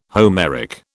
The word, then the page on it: Homeric: British English